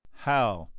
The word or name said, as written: how